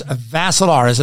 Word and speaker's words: Vassilaris: The last name is Vassilaris. It rhymes with Asilaris, but you add a V.